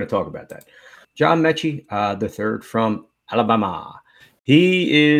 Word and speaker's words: Metchie: We're going to John, how do you say it, Metich? – I think it's just Metchie. – John Metchie, the third.